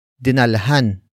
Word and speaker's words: dinalhan: Word for word it's dinalhan, mo, sana, siya, nang, tubig.